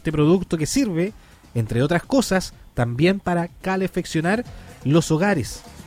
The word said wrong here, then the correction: calefaccionar: Sí, lo dije mal, me dicen. Calefaccionar los hogares, claro.